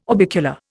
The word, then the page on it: orbicular: Indian